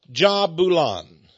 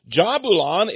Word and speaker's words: Jahbulon: You see, right away you have the J, which can be pronounced Jah or Yah. Then Balon. Now, it's usually spelled J-A-H-B-U-L-O-N. You can pronounce it Bulon or Jabulon, Jabulon, Jabulan.